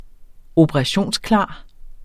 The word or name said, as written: operationsklar